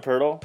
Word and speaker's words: Poeltl: Jakob Poeltl.